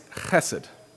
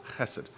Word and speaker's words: chesed: No, it's chesed. Emphasis on that first syllable, chesed.